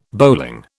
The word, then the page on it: bowling: British English